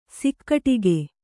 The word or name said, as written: ಸಿಕ್ಕಟ್ಟಿಗೆ